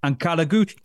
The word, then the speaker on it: Ankaragücü: Right then, how do you pronounce this? Ankaragücü? – Ankaragücü. Ankaragücü. – That's what we'll go with.